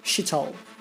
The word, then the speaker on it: Chitole: Louis's story follows the blundering young Ginny Jones, played by Bessie Holland, an orphan from a town with a name spelt C-H-I-T-O-L-E, pronounced shi-toll.